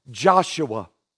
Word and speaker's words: Joshua: But the name Jeshua can also be pronounced this way, Joshua.